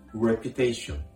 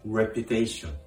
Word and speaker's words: reputation: 評判は英語で reputation もう一度発音します評判は英語で reputation です発音のポイントは五藤の R の発音ですこの R を発音するときはうをいうような形で reputation reputation と発音するとうまくいきますではフランス語で評判どのようにでしょうかフランス語で評判は、レペタシオン。